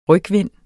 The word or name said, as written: rygvind